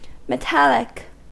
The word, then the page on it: metallic: American English